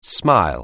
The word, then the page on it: smile: anglais